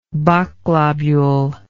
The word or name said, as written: bok globule